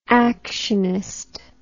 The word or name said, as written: actionist